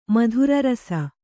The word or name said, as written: ಮದಿರಾರಸ